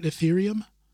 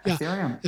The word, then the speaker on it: Ethereum: Ethereum? – Yeah, Ethereum.